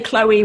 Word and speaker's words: Chloe: Like you said, it sounds more like Chloe and Aubrey because, yeah, we don't say Chloe with a CH. It just doesn't happen.